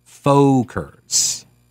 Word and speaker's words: Folkers: It's F-O-L-K-E-R-S, plural. Folkers. Okay? Folkers. Yeah.